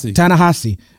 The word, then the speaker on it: Ta-Nehisi: And then Ta-Nehisi, am I saying his name right? Because now I sound like Junior. – Ta-Nehisi.